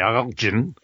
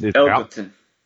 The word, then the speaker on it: Elgton: His first name is E-L-G-T-O-N. – The G is silent. – Is it Elton? – Okay. Yep. – I wondered. I asked your mom, and I'm like, "Oh, it's not Elgin. – It's Elkton.